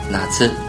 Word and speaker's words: 夏: 呢，no，再给大家拓展一个单词，夏天这个词读作哪次？